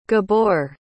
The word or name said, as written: gabor